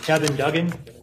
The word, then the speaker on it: Kevin Duggan: It's Kevin Duggan, K-E-V-I-N-D-U-G-G-A-N.